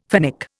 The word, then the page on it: Finnic: Indian